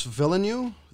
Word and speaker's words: Villeneuve: It's um, Denis Villeneuve, V I L L E N E U V E.